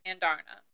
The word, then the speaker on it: Andarna: Which is surprising, especially speaking of Andrian, I can't say her name correct. – Who? – The dragon. – Andarna? – Andarna.